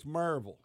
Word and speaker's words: Maryville: Not Maryville, it's Maryville. – Sorry, I said it wrong. – Say that again. – Maryville. It's like Louisville. You don't say Louisville.